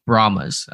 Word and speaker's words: Brahmas: I always want to say Brahmas and put an extra A in there, but I guess it's just Brahmas.